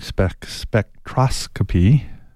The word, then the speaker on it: spectroscopy: How did you come up with the idea of using spectroscopy? Did I say that right? – Spectroscopy.